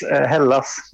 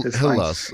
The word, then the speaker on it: Hällas: Is that how you pronounce it? – Not really. It's Hällas, but you can call us whatever you want. – Hällas. Okay, yeah, but yeah.